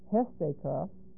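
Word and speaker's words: Hesteka (ἕστηκα): Hesteka, H-E-S-T, long E-K-A, which comes from histamine. Here's the source verb, H-I-S-T-E-M-I.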